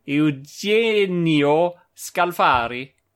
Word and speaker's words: Eugenio Scalfari: I will make an attempt, and you will correct me, of course. Signore Eugenio... No. Signore Eugenio Scalfari.